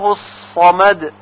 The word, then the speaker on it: الصمد: الله الصمد، الله الصمد، کلمه صمد با صاد باید تلفظ بشه نه با سین.